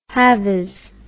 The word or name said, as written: halvies